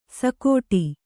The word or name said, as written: ಸೈಗುಡು